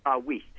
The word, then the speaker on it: Wuest: It's spelled W-U-E-S-T. How do you pronounce it? – Wiest. – Wiest. Okay, great.